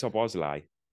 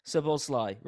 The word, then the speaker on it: Szoboszlai: And then this, how do you say his name? Szoboszlai. – I think it's Szoboszlai. – Szoboszlai, right. We're going to have to learn that one.